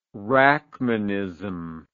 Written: rachmanism

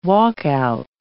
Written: walkout

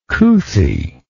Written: couthiness

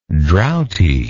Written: drouthiness

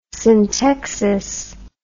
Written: syntexis

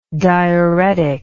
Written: diureticalness

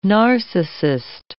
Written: narcissist

